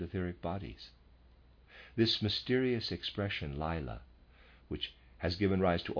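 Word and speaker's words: Laila: Again, I'm pronouncing the word spelled L-A-I-L-A. I'm pronouncing that Lila, end of reader's aside.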